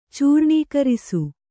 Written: ಚೂರ್ಣೀಕರಿಸು

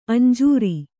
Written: ಅಂಜೂರ

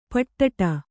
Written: ಪಿಡ್ಡ

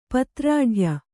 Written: ಪತ್ರಾಢ್ಯ

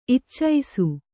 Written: ಇಚ್ಚೈಸು

